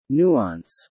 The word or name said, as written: nuance